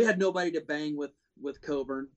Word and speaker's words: Cockburn: And I think AO and Cockburn, however you want to say it. I say Cockburn. – I'm sorry, that's got a CK after the CO, and that's in our language pronounced a certain way, but whatever.